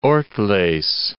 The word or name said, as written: Ortles